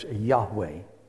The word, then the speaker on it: Yahweh: And that's YHWH, you know, which almost 99% of theologians out there agree that the pronunciation of that is Yahweh.